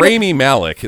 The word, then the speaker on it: Rami Malek: It's Rami. Rami Malek. – I can't say his name. I tried. – It's Rami Malek. It's not that hard.